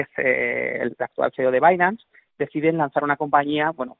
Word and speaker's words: Binance: ¿Lo he dicho bien? ¿Binance o Binance? Binance. Binance. Eh, ¿Cómo nace? – Bueno, Binance nace en 2017.